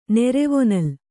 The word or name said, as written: ನೆರೆವೊನಲ್